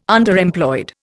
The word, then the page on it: Underemployed: Indian